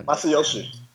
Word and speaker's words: Masayoshi: How do you say it? Ma- Masha San. – Uh, yeah. yeah. Masayoshi.